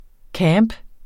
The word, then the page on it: camp: ˈkæːmb